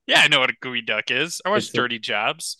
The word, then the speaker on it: geoduck: It's not a geode or a duck. It's actually it's pronounced gooey duck.